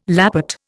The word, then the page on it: lappet: Indian